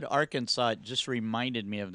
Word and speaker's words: Arkansas: Since the letter S is at the end of the French words are usually silent, we pronounce Bill Clinton's home state Arkansas.